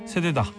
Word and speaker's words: 새되다: 세대다. 짧게 해야겠네 그러면.